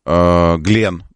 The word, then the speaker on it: Glenn: Согласно трансляциям сайтов из ПСН, мегаракета New Glenn, Glenn, правильно говорить, ну, в честь астронавта, компания Blue Origin взорвалась во время испытаний на стартовой площадке на мысе Канаверал.